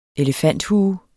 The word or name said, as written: elefanthue